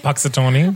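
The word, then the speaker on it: Punxsutawney: Puxatawny.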